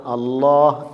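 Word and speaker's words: അല്ലാഹ് (الله): പക്ഷേ അല്ലാഹ് എന്നതിനെ ഒന്ന് കനപ്പിച്ച് പറയലാണ് തഫ്ഹീം. അപ്പം ലാമിൻ്റെ തഫ്ഹീമിൻ്റെ ഉച്ചാരണമാണ് അല്ലാഹ് എന്നത്.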